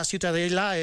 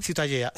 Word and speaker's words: Ciutadella: Pero bueno, para mí la Ciutadella es... ¿cómo se pronuncia? – La catalán? – Ciutadella. – Ciutadella.